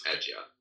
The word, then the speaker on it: Adyar: Am I pronouncing Adyar right? – You are, yes, Adyar.